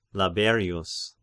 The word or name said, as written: Laberius